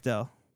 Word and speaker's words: Bechdel: This tiny little scene passes the blood belch. How do you pronounce that? – Bechdel. – Bechdel, thank you.